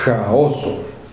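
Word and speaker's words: ĥaoso: Luego tenemos la H con circunflejo, que se pronuncia más fuerte que la H sin circunflejo. Se pronuncia jo. Por ejemplo, caos en Esperanto es jaoso.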